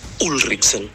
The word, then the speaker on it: Ulrichsen: Si me permiten un humilde aporte, el nombre de la calle debería pronunciarse acentuando la U, Ulrichsen, ¿no?